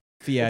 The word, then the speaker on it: Fieri: It's Fieri. It's in the works. – Or as Mason would say it, Fieri.